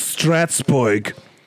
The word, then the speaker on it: Strasbourg: Patrick, but if you're going to say it correctly, it's Strasbourg.